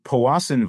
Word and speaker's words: Powassan: So Powassan virus, at least that's how I understand it's pronounced.